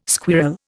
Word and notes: squirl: Indian

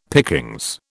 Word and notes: pickings: British English

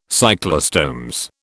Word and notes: cyclostomes: British English

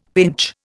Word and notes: pinch: Indian

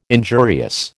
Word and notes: injurious: American English

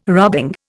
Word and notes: rubbing: Indian

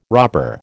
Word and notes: robber: American English

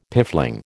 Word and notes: piffling: American English

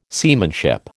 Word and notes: seamanship: American English

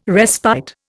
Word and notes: respite: Indian

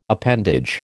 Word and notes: appendage: American English

